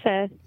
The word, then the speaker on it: pho: I was going to say, my favorite soup is, I believe it's a Vietnamese, I think some people think it's pronounced pho, but I asked the guy, I believe it's pronounced pho.